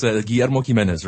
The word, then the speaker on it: Guillermo Jimenez: And one man is Guillermo Jimenez, and he is, did I pronounce that? Help me with the pronunciation here. – Yeah, that was pretty close. Guillermo Jimenez, right.